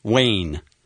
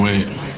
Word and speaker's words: Wade: Dwayne Wade. – Dwayne Wayne. – Yes, Wade. – Wayne? – Wade. – Wade? – Wade. – Wade? – Wade. – Wade? – Yes. – How do you spell it? – W-E-D.